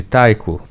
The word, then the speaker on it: taiko: Bom, vamos agora falar sobre o grupo de taiko. Essa é a pronúncia. – Acertei. – Taiko. – Isso.